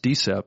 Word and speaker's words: DSIP: It's an acronym, said, you know, pronounced DSIP by the Drug Enforcement Administration.